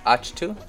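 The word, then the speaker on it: Ahch-To: How do you say this plant's name? – Achtu. – Achtu. I was like, it sounds like a sneeze.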